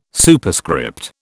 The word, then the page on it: Superscript: British English